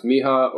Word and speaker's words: Mija: And then this artist that I'd never heard of prior to, but we just ended up catching at Jubilee, but it's M-I-J-A, so I don't know if it's pronounced Miha or Mija. I think that's right.